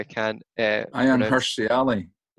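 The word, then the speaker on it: Ayaan Hirsi Ali: who's married to Ayanna Lee, let's call her. – Ayanna Hirsi Ali. – Yeah, there you go. You can pronounce her middle name.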